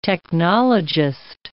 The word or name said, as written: technologist